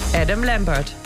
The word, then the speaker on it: Adam Lambert: Adam Lambert.